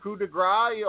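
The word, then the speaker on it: coup de grâce: I mean, that coup de grace, oh, God, I said it right.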